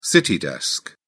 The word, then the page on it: city desk: British English